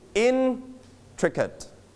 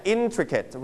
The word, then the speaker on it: intricate: นักเรียนปลายทางอย่าลืมออกเสียงตามไปด้วยนะครับ So everybody, we put the stress on which syllable? The first, second, or third syllable? Where is the stress? First syllable. So we pronounce it intricate. Okay. In เสียงสูงที่พยางค์แรก intricate.